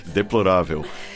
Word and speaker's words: deplorável: Deplorável.